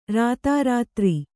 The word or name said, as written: ರಾತಾರಾತ್ರಿ